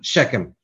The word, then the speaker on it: Shechem: Shechem. – Shechem in Mount Ephraim, and dwelt therein, and went out from thence and built Penuel.